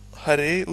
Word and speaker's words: Hare: Så Hare udtales med R i ganen, hvis du kan finde ud af det.